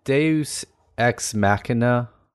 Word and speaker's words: deus ex machina: Is it Deus Ex Machina? – Yeah.